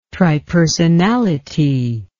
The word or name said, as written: tripersonality